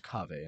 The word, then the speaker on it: qahweh: And the word caffeine is derived from the Arabic word Kave. – It's spelled Q-A-H-W-E-H, but it's pronounced Kave in Turkish.